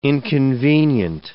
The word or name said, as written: inconvenient